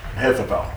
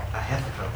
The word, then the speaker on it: Ahithophel: Ahithophel. – Ahithophel, pardon me.